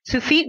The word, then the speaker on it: Tsufit: I have to ask you, your spelling of your name is T-S-U-F-I-T. So you wouldn't naturally pronounce that Sufit. Where did that come from? – Sufit means hummingbird or sunbird in Hebrew.